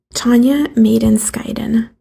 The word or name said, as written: Tanya Maden Skydan